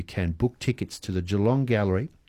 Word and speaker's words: Geelong: We have very little time, but I have to say I was very impressed, Marianne, with your pronunciation of Geelong.